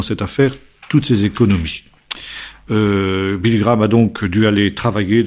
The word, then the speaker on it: Billy Graham: Billy Graham, si on veut employer la prononciation américaine.